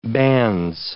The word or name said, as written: banns